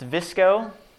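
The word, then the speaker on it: VSCO: The second one is pronounced Visco.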